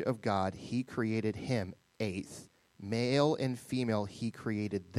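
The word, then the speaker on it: aith (את): The word is aith, A-Y-T-H, aith is how you pronounce that.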